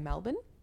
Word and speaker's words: Melbourne: Think it's so funny that we say Melbourne and not Melbourne. – What do you mean? – We pronounce it with an A.